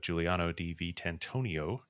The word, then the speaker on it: Giuliano Di Vitantonio: And I want to thank our guest, Giuliano Di Vitantonio.